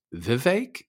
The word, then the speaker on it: Vivek: Politico wrote, Vivek, learn how to pronounce his name. It rhymes with cake. So I guess they're saying that his name is pronounced Vivek. Is that right? – That is correct.